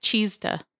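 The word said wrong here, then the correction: chesed (חסד): The Hebrew word for mercy is C-H-E-S-E-D-H. – Yeah, that's kesed, I think. – Okay. I like my pronunciation better. – You have to really the C-H. – You gotta get chesed.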